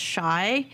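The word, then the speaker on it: Shai: It's S-H-A-I. It's pronounced Shy.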